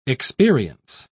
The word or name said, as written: experience